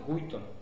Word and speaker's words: Guyton: Y así han habido libros que por alguna razón no han tenido o no han conservado el lugar que ha conservado el Gaito. – ¿El ese cómo se escribe, perdón? – Guito, con Y. Guito. – Con G-U-Y-T-O-N. – T-O-N.